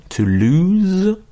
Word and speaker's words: lose: Et au niveau de la prononciation, to lose et loose.